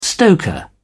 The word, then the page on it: stoker: British English